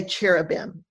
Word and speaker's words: cherubim: We say cherubim in English is how we pronounce it, and it's the plural of cherub.